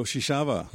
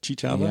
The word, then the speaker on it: Chichava: Welcome, Ugo Shishava. – Hello, hello, hello. – Did I pronounce that right? Shishava? Chichava? – Yeah, it's Shishava in Portuguese. And in English, like some people will say, it's like Chichava.